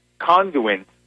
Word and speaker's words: Conduent: So Conduent, the company that tasked with overhauling SunPass and has failed so miserably so far, it's spelled C-O-N-D-U-E-N-T, Conduent, and I actually just tweeted, if you go on Noah Pransky at Twitter, you'll see I was tweeting about them this morning.